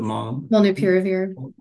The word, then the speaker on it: molnupiravir: Molnupiravir.